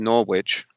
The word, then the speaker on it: Norwich: It's actually pronounced Norwich, and they will finish top three.